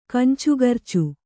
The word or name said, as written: ಕಂಚುಗರ್ಚು